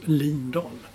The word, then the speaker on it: Lindahl: Och enligt vår vän Olof Lund så påstår han att det var hon själv som har sagt att hennes namn ska från och med nu uttalas Lindahl.